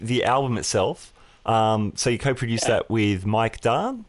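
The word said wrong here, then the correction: Daan: did I pronounce that? – Yeah, Mike Dane, actually. D-A-A-N.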